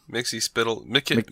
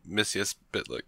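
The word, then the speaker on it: Mxyzptlk: We got Mr. Mixer Spittle next week. I said that right? – Mixer Spittlick. – Let's see how this show pronounces it, then. I think he did it. I think he did Mixer Spittlick.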